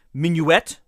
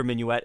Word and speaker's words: Minuit: Minuet might have been the way people pronounced his name, seeing as how the Germans would like to pronounce every letter in a word, even to this day.